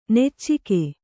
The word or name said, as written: ನುಚ್ಚಕ್ಕಿ